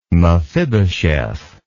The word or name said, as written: mephibosheth